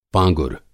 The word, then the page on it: पाँगुर: Hindi